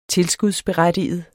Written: berettige

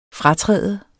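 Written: FRATRÅDTES

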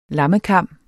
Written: lammekam

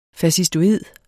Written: fascistoid

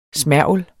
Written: smergel

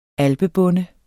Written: alpebonde

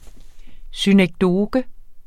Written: synekdoke

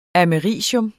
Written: americium

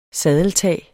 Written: saddeltag

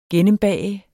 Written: gennembage

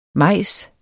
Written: mejs

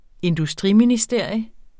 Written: industriministerium

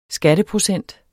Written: skatteprocent

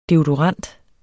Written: deodorant

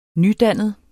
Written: nydannet

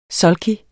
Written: sulky

